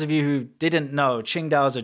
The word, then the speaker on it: Qingdao: But it's pronounced Qingdao, same as the city.